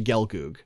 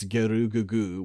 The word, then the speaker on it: Gelgoog: And Gelgoog is, you know, obviously you say Gelgoog, and which is two syllables, and in Japanese, because of how this works, it's Gerugugu, which is four fucking syllables.